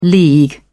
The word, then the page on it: league: British English